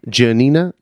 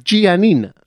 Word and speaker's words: Janina: Janina? Yeah. Janina. I think they overpronounce it like Giannina.